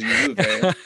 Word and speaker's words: Juve: Instead of Juve, it is Juve.